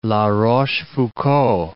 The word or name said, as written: La Rochefoucauld